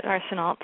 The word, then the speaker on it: Arsenault: Yeah, that's how I pronounce it, Arsenault. Americanized.